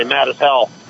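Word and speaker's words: Mazatzal: And there was a point, let me tell you, on the Arizona Trail where this is in the Mezital, the mountains outside Tapan, which if you want to pronounce it like the local, you say mad as hell.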